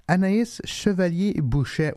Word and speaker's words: Anaïs Chevalier Bouchet: Anaïs Chevalier Bouchet, which first of all is this beautiful name.